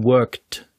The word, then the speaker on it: worked: Dabei müsste es richtig heißen worked. Worked mit einem D.